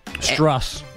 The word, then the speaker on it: Strus: Struss. That's what Caserta called him yesterday.